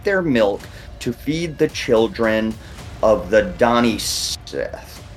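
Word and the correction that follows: Daoine Sìth: I said Sith, but it should be she. It's like the Donnie she or something like that.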